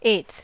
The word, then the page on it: eighth: American English